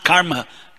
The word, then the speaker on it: karma: So you said, say you said karma. It's not karma. Karma, like the U in but, ka.